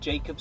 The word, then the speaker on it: Jacob: And if you do from this podcast feel like you're going to put something new out, message me, find me on LinkedIn, Jacob Sotiris. And Jacob is spelled J-A-C-O-B, Sotiris is spelled S-O-T-I-R-I-S.